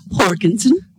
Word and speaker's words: Jorgensen: the John Jurgensen? – Yeah, Jorgensen. – You could say that. – Do you say that? Did you pronounce the J? – Yes. – Jorgensen.